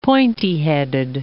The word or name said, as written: pointy-headed